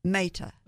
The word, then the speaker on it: Mehta: Mehta.